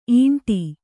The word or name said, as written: ಇಂಡೆ